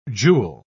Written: jewel

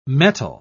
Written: mettle

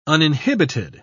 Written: uninhibited

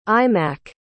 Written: imac

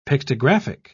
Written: pictographic

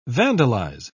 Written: vandalize

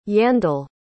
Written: yandel

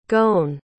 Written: goan

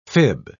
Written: fib

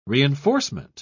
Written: reinforcement

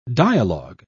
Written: dialogue

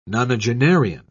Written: nonagenarian

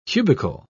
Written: cubicle

